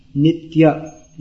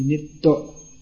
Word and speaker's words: nitya: There's another, when it comes after, just like nitya, in Sanskrit, nitya becomes in Bengali, nitto. The y becomes almost silent.